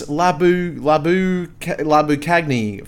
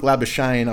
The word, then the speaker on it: Labuschagne: It's about 10 or so minutes before lunch, and Minus Labuschagne comes on the bowl, and my sister's like, who's this Labu Cagney fella? And I'm like, look, Labuschagne, I've only learned how to say it this year myself, sis, so I know where your head's at there.